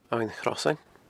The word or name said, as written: Abhainn Chrosain